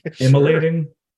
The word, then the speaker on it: immolating: Oh, your sex is so much better than emoliating my dick. I'm saying that right, right? immolating.